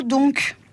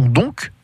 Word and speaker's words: donc: Alors le mot donc, il a cette particularité de pouvoir se prononcer de deux façons, donc ou don. On peut trouver la prononciation don dans la phrase mais où est-il don on va s'arrêter là. Généralement, on euh, on prononce pas le C, mais où est-il don Eh bien, il s'agit du mot donc, D-O-N-C.